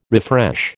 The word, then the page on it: refresh: American English